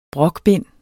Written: brokbind

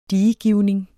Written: diegivning

